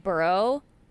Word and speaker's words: Burrow: And I just want to say that I'm most annoyed by everyone pronouncing Joe Burrow his name wrong and adding an S at the end, just in day-to-day conversation.